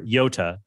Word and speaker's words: iota: When you had in the early church, you had a dispute about, well, is Jesus, there's that phrase, it doesn't make one iota of a difference to me. Iota, or yota, to pronounce it more correctly, is the Greek letter I in the Greek alphabet.